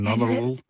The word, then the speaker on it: Nannerl: En samen met zijn zusje Nannerl, die heet bij ons Nanny. Ik kan het niet eens uitspreken. Nannerl. – Nannerl. – Ja, dat is Oostenrijks, denk ik.